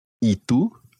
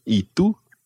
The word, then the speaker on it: ¿Y tú?: ¿Y tú? – ¿Y tú? – ¿Y tú? – ¿Y tú? – That's it. Now watch your pronunciation of the Spanish u, ¿y tú? – ¿Y tú?